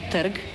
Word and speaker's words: trg: Par exemple, le mot la place, qu'on dit terg, donc T-R-G, sans savoir qu'il y a un E muet à insérer devant le R.